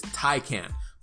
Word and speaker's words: Taycan: I want to say Taycan because the thing is spelled T-A-Y-C-H-A-N. Or maybe it's Taycan. I'm just kidding. The way they pronounce it is Taycan.